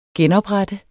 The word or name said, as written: genoprette